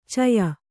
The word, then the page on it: ಛಾಯ: Kannada